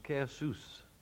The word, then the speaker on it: Caersws: C-A-E-R-S-W-S. How would you pronounce that? I've never... – Well, I happen to know where it is. – Do you? – And it's pronounced Caersus.